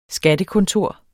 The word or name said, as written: skattekontor